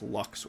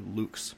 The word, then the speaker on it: lux: It's pronounced Lux or Lux, depending on the pronunciation We don't know the perfect pronunciations for Latin.